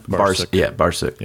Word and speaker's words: Barsuk: And then from that, I started paying attention to labels like, I think I'm pronouncing it right, was it Brusak that they Barsuk. Were on at the time? – Barsuk. – Yep.